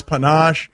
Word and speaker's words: panache: I pronounced it as panache, which sounds like a delightful French sandwich of some sort when it's panache.